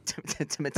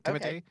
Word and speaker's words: Timothée: Timothée. – That's how you say his name. Timothée or Timothée. Timothée.